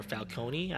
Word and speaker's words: Falcone: So, by the way, that's Melissa McCarthy's real life husband, Ben Falcone or Falcone. I don't know. I don't know if that you pronounce that E or not. It's a silent E.